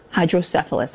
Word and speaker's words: hydrocephalus: But he says that he underwent surgery after being diagnosed with normal pressure hydrocephalus.